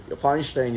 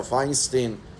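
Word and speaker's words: Feinstein: راو بزرگ آمریکا بودن، ریبی موش فاینشتین یا فاینستین، هر دو تلفظ میشه.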